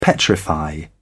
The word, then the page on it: petrify: British English